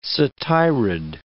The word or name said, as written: satyrid